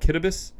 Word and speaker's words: Kiribati: Kiribati, as it's spelled, Kitabus, as it's pronounced.